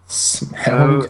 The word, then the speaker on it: Smaug: Smaug? Is that how you pronounce it? – Yeah.